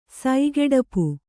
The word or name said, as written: ಸೈಗೆಡಪು